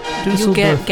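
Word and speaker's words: Düsseldorf: And they're from Dusseldorf. Did I pronounce it correctly that time? – Yes. Dusseldorf. Getting better.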